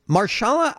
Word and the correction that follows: Mahershala: Ali, is that how you pronounce his first name? – Mahershala. – Mahershala Ali.